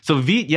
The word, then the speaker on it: Vite: Yeah, I think Evan takes all his names from the French pronunciations. So Vite,